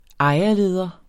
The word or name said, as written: ejerleder